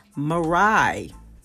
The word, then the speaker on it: Mirai: Mirai, that's the ice skater. That's how she pronounced her name.